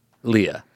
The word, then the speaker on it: Leah: What do you go, Leia or Leah? – How do you spell it? – L-E-E-Y-I-A-A-U-H-A. – Leah. – Leah. – Leah. – No, it's L-E-A-H. – Leah. – Yes, Leah.